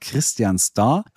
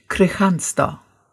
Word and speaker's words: Kristianstad: Christianstad, das D lassen wir gleich mal wieder wegfallen, hinten dran, also hätten wir noch Christianstad, aber das passt immer noch nicht so richtig. Wie wird dieser Ort ausgesprochen? – Ja, der Ort wird ganz lustig ausgesprochen, das ist nämlich Kristianstad. Kristianstad, ganz langsam, ja? Also da haben wir dieses K-R-I und dann haben wir ein S-T, wo man eigentlich sagen würde, ja, Moment, das S-T wird doch aber ausgesprochen.